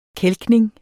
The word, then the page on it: kælkning: ˈkεlgneŋ